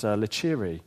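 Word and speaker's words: licere: Interesting, the root word of leisure is from a Latin word, which is pronounced lecere, which literally means permission.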